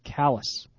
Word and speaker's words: Calais: C-A-L-A-I-S. I think we were probably saying Calais, as in French, but he says it's pronounced Callis. – As in L-O Cool J? – C-A-L-L-U-S.